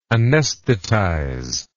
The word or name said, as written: anesthetization